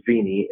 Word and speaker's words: Veny: And my name is Mike Veny.